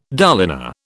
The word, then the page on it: dolina: British English